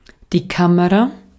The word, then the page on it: appareil photo: /a.pa.ʁɛj fɔ.to/